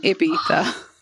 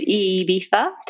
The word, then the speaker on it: Ibiza: How is Ibiza? I I said that wrong. Ibiza. Well, the Spanish way is Ibiza, um, but Ibiza is well recognized also.